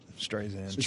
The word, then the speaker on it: Streisand: Make sure you pronounce it right. Streisand.